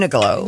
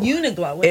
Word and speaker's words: Uniqlo: How do you pronounce this name? – Uniqlo. – Uniqlo.